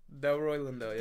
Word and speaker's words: Delroy Lindo: Delroy Lindo.